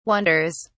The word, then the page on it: wonders: British English